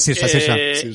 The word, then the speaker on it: Saoirse: Vale, entonces Saoirse. Creo que lo he pronunciado bien. – No, es Saoirse.